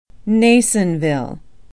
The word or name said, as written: Nasonville